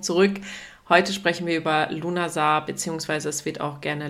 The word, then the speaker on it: Lughnasadh: Ja, Lunasar wird anders ausgesprochen als geschrieben. Als ich das erste Mal das geschriebene Wort gesehen habe, dachte ich erst so, um Gottes Willen, wie soll ich das aussprechen? Dann kam aber die Erlösung und es wird einfach ganz simpel Lunasar ausgesprochen.